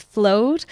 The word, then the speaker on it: Flowd: so it's Flow, F L O W with a D on the end. Some people get the name wrong. It's Flowd.